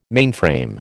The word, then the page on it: mainframe: American English